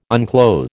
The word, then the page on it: unclothe: American English